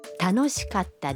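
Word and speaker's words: Таношикатта (楽しかった): Катта произносится с короткой паузой между ка и та. – Таношикатта дес.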